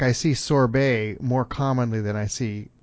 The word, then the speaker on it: sorbet: The original term is what the French call sorbet, S-O-R-B-E-T. It's Turkish and Persian, and I don't know how it's pronounced in those languages. The French do sorbet, and you often find in ice cream shops, well, they'll use the term sorbet on the menu.